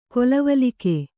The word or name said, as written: ಕೊಲವಲಿಕೆ